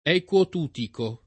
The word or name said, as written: Equo Tutico